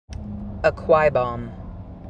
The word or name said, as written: Akwa Ibom